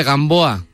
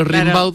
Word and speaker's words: Rimbaud: Gimbaud. – Ahí va. Claro, como se escribe, se pronuncia Rimbaud, pero Rimbaud.